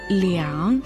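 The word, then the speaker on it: 两: Слово «лян» произносится в третьем тоне, означает «два», «оба».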